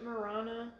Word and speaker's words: Marana: We went about 30 minutes north of Tucson, Marina. – I think they pronounced it Marana.